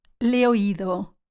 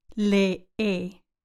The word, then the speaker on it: le he oído: For example, le he oído. Notice how I don't say le e, but le, le, with a long e, rather than two e, e sounds.